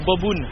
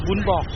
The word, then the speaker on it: bún bò: Les Français disent boboon, mais il faut bien prononcer bún bò.